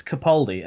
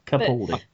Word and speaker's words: Capaldi: Before we started the show, we actually had a bit of a discussion about how to pronounce his surname, and I think it's Capaldi, as in, like, almost like Baldy. But with a K and the P instead of the B. So, Capaldi.